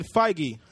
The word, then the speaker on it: Feige: Kevin Feige.